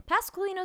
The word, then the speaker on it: Pasqualino: Pasqualino is a tricky name, so I'm going to spell it for you very slowly. P-A-S-Q-U-A-L-I-N-O.